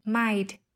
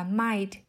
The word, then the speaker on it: might: Còn ở tập này với từ might, thay vì đọc là might thì chúng ta có thể đọc là might, chặn hơi ở cổ họng.